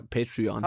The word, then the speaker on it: Patreon: Ja, Patreon.